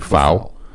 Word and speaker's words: Pfau: Pfau is actually, the actual pronunciation is Wade Pfau.